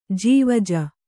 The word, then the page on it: ಜವಾಜಿ: javāji